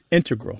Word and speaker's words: integral: Just to clarify here, both correct, but the mathematicians and math-using scientists are more likely to say integral with the stress on the first syllable.